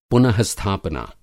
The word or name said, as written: पुन:स्थापना